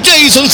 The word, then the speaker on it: Jason: 2, la igualdad para el cuadro del general, y aparece Jason, así se escribe, Jason se pronuncia, Lorca, en el minuto 34 para colocar las cosas 1-1, cuando nadie se lo esperaba, aquí está el 1-1.